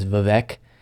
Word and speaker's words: Vivek: They call it, actually, his name is Vivek, like cake. But I thought he was very good. – He is actually correct. I, for a while, mispronounced it as Vivek. And a lot of people have said Vivek and all these different things. It is Vivek.